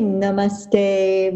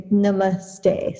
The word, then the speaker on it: namaste: And we don't say namaste, we say namaste. So it's the emphasis is on the second syllable.